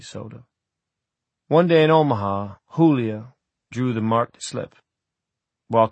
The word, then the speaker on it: Julia: He gave her name the Spanish pronunciation Julia.